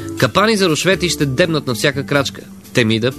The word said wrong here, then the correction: Темида: Така, вижте как, като не сложиш грешно ударение и акцент, как се обърква цяло изречение. Започвам от начало. Капани за рушвети ще дебнат на всяка крачка Темида, предвижда още стратегията.